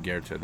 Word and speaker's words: Goethe: His name is spelled Goethe, but I think it's pronounced Goethe.